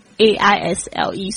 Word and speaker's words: aisle: 这个 sle 这样子的组合呢，通常会就是这种 isl 这样子组合的字呢，通常呢这个 s 是不发音的哟，所以呢这个 isle 是 isle 不是 isle， OK aisle 虽然是这样拼，但是 s 把它忘记， ilc 就是走到的位置。